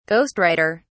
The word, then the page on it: ghost writer: British English